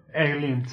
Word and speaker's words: Eileen: Eileen, sí.